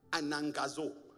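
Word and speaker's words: anagkazo: The real pronunciation of anakazo is anangazo. But because I'm the one preaching, say anakazo. We're going to keep it at anakazo.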